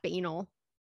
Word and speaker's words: banal: And he says, or she says, or they say, it's in the script for Neil Simon's Rumours, and the actress keeps pronouncing it as if it rhymes with anal. And that drives me bonkers. – Good. I like that. – So I thought that was funny because banal, that would drive me crazy.